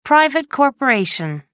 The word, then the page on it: private corporation: American English